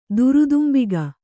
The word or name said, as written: ದುರುದುಂಬಿಗ